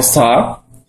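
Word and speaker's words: sa (사): Numer 4 po koreańsku, wymawiany jako sa, znaczy to samo, co śmierć, które po chińsku, w znakach chińskich, jest również wymawiane jako sa.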